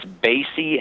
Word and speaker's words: Basie: It's Basie, as in Count Basie.